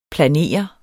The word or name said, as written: planere